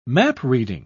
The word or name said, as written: map-reading